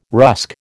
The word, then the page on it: rusk: American English